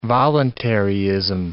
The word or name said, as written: voluntaryism